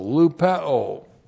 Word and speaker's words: lupeo: Lupeo looks like this in the Greek. L-U-P-E, long O, lupeo.